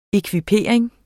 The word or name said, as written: ekvipering